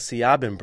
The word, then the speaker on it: Siobhan: My first name is spelled S-I-O-B-H-A-N. – Just like Siobhan Brooks spells hers. – Yeah, but I say my name Siobhan.